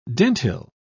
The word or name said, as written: dentil